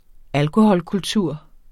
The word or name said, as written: alkoholkultur